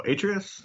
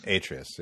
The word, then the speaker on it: Atreus: Oh, and I thought that, how do you say his name? Because I butcher it every single time. The boy. – Oh, Atreus? – Atreus, yeah. Like, I really enjoyed how they used him.